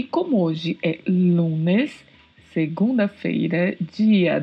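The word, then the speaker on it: día: significa dia. A diferença é que é escrito com um acento agudo no i e a pronúncia é di. Em espanhol, não temos a pronúncia di.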